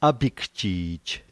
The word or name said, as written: apikjij